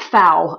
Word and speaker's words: pho: Now, Tycho, do you remember the episode of Judge John Hodgman where the argument is about how to pronounce the Vietnamese soup that is spelled P-H-O? Pho. – Yeah. – I had never heard it pronounced foul until this movie.